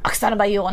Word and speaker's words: Oksana Bayul: And my dad used to make fun of me and be like, Oksana Bayul. And I was like, that's how they pronounce it, Dad.